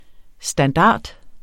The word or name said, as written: standart